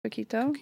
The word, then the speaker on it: coquito: Our wonderful audio engineer this morning was just telling me about coquito.